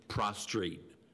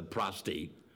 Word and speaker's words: prostate: And then you sort of sound stupid, like calling it the prostrate instead of the prostate.